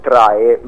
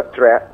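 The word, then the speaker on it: Træ: Ma io, senza stare a dilungarmi troppo con le parole, farei parlare la musica, quindi sceglierei la traccia numero 4, che italianizzata sarebbe Trae, ma si pronuncia Tre perché è dall'alfabeto danese, che vuol dire albero in lingua danese, quindi niente, io ascolterei questa canzone.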